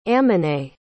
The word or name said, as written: amenae